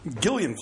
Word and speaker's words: Gillian: Is it Gillian? – Yeah. – Okay. – The hard G. – Gillian Flynn.